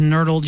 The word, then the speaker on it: nurdle: Merriam-Webster Dictionary, nurdle, N-U-R-D-L-E, spelled like hurdle, but with an N in front. Not like nerd, N-E-R-D. It's nurdle.